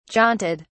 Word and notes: jaunted: British English

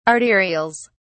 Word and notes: arterials: British English

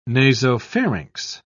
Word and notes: nasopharynx: British English

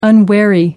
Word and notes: unwary: American English

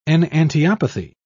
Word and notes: enantiopathy: British English

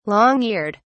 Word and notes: long eared: British English

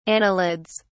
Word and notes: annelids: British English

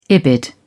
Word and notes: ibid: British English

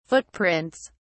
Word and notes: footprints: British English

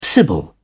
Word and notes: sibyl: British English

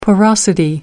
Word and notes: porosity: American English

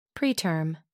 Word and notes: preterm: American English

